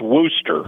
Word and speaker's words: Worcester: It's pronounced Worcester.